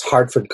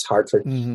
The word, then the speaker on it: Hertford: Yeah, sorry to interrupt, but the middle common room at my college, which is Hartford College, which looks as though it's spelled Hertford College, but it's English, so it's Hartford, after a heart, you know, the like deer-like animal.